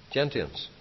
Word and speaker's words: gentians: Gentians. Is that how you pronounce it? Sorry? Gentians? Gentia? Yeah, okay, great, sorry.